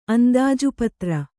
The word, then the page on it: ಅಂದಾಜುಪತ್ರ: andājupatra